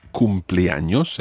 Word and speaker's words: cumpleaños: Okay, the word is cumpleaños, el cumpleaños. And it's actually made up of two words in Spanish. Cumplir, which is the verb to complete or to reach. And años, meaning years. So cumpleaños is kind of the number of years you have reached.